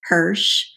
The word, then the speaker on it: Hersh: I do have a website that's simply my name, Sharon Hersh, and Hersh is spelled H-E-R-S-H. If you like chocolate, it's like Hershey.